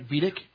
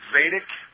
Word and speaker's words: Vedic: Did I pronounce it right? Vedic? – Well, actually, it's called Vedic.